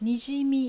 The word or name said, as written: Nijimi